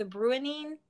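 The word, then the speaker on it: Bruinen: The Brunnin? – I have no clue.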